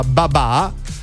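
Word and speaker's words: babà (爸爸): È la festa del papà in Cina, in mandarino infatti la parola padre si pronuncia babà, come quello che invece qua si mangia buonissimo, perché l'8 agosto, 8-8, si pronuncia proprio babà.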